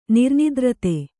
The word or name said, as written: ನಿರ್ನಿದ್ರತೆ